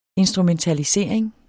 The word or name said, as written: instrumentalisering